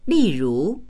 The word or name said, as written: li4ru2